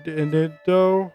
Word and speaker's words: Inindo: Inindo.